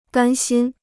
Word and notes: 甘心: gān xīn

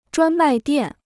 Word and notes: 专卖店: zhuān mài diàn

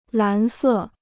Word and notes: 蓝色: lán sè

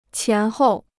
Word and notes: 前后: qián hòu

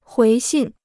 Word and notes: 回信: huí xìn

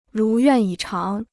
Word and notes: 如愿以偿: rú yuàn yǐ cháng

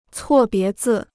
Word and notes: 错别字: cuò bié zì

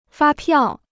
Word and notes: 发票: fā piào

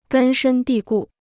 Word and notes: 根深蒂固: gēn shēn dì gù

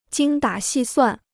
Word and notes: 精打细算: jīng dǎ xì suàn

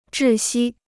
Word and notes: 窒息: zhì xī

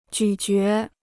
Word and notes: 咀嚼: jǔ jué

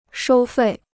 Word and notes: 收费: shōu fèi

